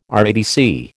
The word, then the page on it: RADC: American English